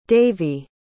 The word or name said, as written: davy